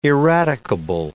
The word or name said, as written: eradicable